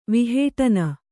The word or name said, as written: ವಿಹೇಟನ